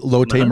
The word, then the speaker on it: Lotte: Lotte Marines. Yeah. – Lotte Marines.